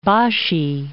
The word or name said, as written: Bashi Channel